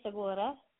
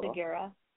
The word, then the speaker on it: Segura: The Spanish way is Segura, and the English way is Segura.